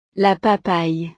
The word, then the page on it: la papaye: frances